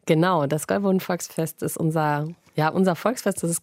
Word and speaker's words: Gäuboden-Volksfest: Hier steht Besuch auf dem Gäuboden-Volksfest, richtig ausgesprochen? – Ja, das ist auch gleich mein Favorite.